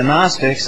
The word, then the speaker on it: Gnostic: Gnosticism, or the Gnostics, and the Gnostic is spelled with a G, so it's Gnostic with a G in front of it.